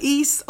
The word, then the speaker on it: Ys: East. Yeah. So people say like East, or some people say the youth, or you know.